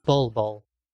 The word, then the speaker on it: Bol Bol: So I have, he's also a power forward position, Bol Bol. So he's owned in 47% of leagues. Up 43. – What was the name? – Bol Bol. – How do you spell that? – B-O-L space B-O-L.